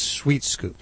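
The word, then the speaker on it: Swheat Scoop: It's spelled S-W-H-E-A-T. Because it has weed in it, so it's Sweet Scoop.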